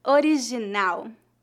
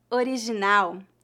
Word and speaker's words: original: original, original, original. – Note que a letra G antes da vogal I é pronunciada com uma letra S na palavra treasure.